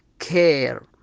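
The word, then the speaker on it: care: الكلمة اللي بعدها كير، نطقنا الكلمة كير ما نطقناها بكاري، السبب لأن قلنا حرف الإي يكتب ولا ينطق، يكون سايلنت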